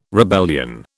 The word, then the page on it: rebellion: British English